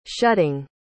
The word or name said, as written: shutting